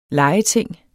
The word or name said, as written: legeting